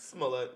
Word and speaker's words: Smollett: I don't want to mispronounce his name. Jesse Smollett, is that correct? – Smollett, yeah. – Smollett, yes. – Smollett. Jesse Smollett.